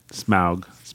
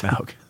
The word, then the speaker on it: Smaug: Smaug. I believe is how it's pronounced. – Smaug.